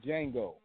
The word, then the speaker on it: Django: I had to do with the Django with the whip scene with Black History. – The D is silent. It's Django. What? – Can I say on that?